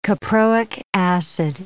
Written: caproic acid

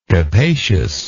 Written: capaciousness

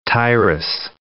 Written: Tyrus

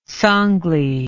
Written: Sangli

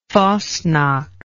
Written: fasnacht

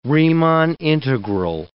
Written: Riemann integral

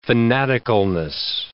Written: fanaticalness

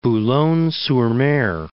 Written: Boulogne-sur-Mer

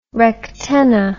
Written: rectenna

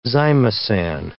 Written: zymosan

